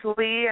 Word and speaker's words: Lea: My name is Audrey, and then my middle name is Lee, and it's spelled L-E-A, and my last name is Curry.